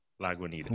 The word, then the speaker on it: Lagunitas: Lagunitas, right? Lagunitas. – Yeah. – See, I think I was definitely pronouncing it incorrectly previously.